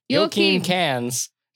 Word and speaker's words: Joakim Cans: Joakim Cans describes the creation of We Make Swedish Rock.